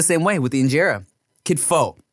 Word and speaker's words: kitfo: So one thing they'll do is this thing called kitfu, right? Where it, let me see if it's kitfu or kitfo. Let me make sure I'm saying that right.